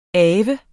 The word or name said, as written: ave